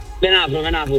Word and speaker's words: Venafro: E allora, ho detto giusto il Venafro? – Venafro, sì, perché tu, come sai, mi ricordo bene i nomi di Reggio, ma gli altri un po' di meno.